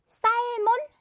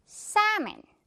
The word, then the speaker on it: salmon: 너무나 살몬, 셀몬처럼 생겼지만 여기에 지금 있는 이 L은요. 유령 친구입니다. 무서운 유령이라서 연기처럼 이렇게 사라지는 소리가 나요. 바로 Salmon. 이렇게 발음이 난다는 거예요.